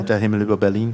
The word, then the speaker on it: Der Himmel über Berlin: Der Himmel über Berlin.